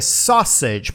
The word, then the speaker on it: sausage: Outra palavra complicada é salsicha em inglês, que é sausage, porque ela tem uma maneira diferente de escrever, é S-A-U-S-A-G-E.